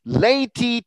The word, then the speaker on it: Laeti: Notice you pronounce A-E as A in Catholic Latin. Laeti triumphantes, venite, venite.